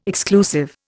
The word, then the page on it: exclusive: Indian